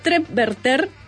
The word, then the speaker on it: trepverter: Después está esta palabra que se pronuncia Trepverter,